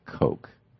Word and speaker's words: Koch: I think they pronounce it Coke, but it's spelled K-O-C-H.